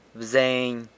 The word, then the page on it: bzeiny: San Lucas Quiaviní